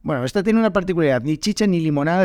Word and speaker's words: ni chicha ni limonada: decimos ni chicha ni limoná, ni chicha ni limoná.